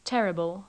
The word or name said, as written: terrible